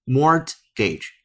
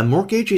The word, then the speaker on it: mortgage: M O R T G A G E，M O R T G A G E，错误的读音是 mortgage，这是错的。正确读音字母 T 不发音，mortgage，mortgage。 A mortgage is a loan of money which you get from a bank or savings and loan association in order to buy a house.